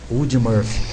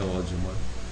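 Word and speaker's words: Audie Murphy: A-U-D-I-E, primeiro nome. Ode. Depende do estado, né? Pronuncia de maneira diferente, mas o mais certo é Ode. E a segunda é M-U-R Murphy. M-U-R-P-H-Y. – Ode Murphy. – Uh-huh. Em alguns lugares se pronuncia Ode mesmo, no interiorzão principalmente, mas é Ode Murphy.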